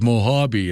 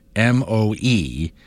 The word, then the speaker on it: Mojave: And the name of the desert is the Mojave Desert. And people have literally laughed in my face. It's Mojave. It's a Spanish word.